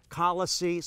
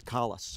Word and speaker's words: Colossae: Sometimes it's pronounced Colossae, sometimes Colossae. I'm just pronouncing it Colossae.